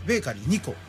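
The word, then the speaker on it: ベーカリー25: であと沼津のですね足高根形街道沿いにあるベーカリー 25 と書いてベーカリー 2個なんですけれども、はい、ここのオニオンブレッド遅い時間では買えないんですけれども、僕大ファンなんですね。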